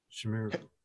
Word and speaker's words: chimeras: So they're almost like Chimerians. Is that how it's pronounced? – Chimeras. – Yeah. – No, these things are chimeras.